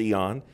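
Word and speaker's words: eon (αἰών): The word world here means eon, or it's pronounced eon, and it means this.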